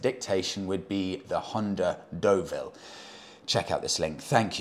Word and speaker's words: Deauville: What was I calling it? The Juville, I think. Semi awkwardly pronouncing where I knew I was wrong.